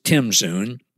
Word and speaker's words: tzimtzum: Well, it works by Zoom, Z-O-O-M. Actually, Zoom power. Now, I'm really referring to a Hebrew word here that some people pronounce Zoom, Zoom. It's spelled T-Z-I-M, T-Z-U-M. More likely, it's pronounced Tim-Zoom, but that's not as cool, in my opinion.